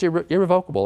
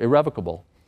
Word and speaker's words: irrevocable: So many people think that if they create an irrevocable trust, just by virtue of the fact that it's irrevocable or irrevocable, by the way, the dictionary says you can pronounce it either way, in case you're wondering.